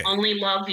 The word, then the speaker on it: Onleilove: It's Onleilove. – Onleilove, okay. – Onleilove, you, O-N-L-Y-L-O-V-E.